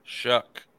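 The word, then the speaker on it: Shough: And it is pronounced Tyler Shuck. – Shuck. – Shuck. I don't know Shuck. Why those things bother me a lot because I'm into grammar, but it is. And I just checked his Twitter account and he was correcting someone else and said it is pronounced Shuck, just as you would think.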